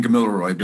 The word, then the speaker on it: Gamilaroi: Have I got my pronunciations right? – Close enough, sister. – Okay. It's Wiradjuri and Gamilaroi, but you have to grow up speaking it, you know, to get your mouth around that.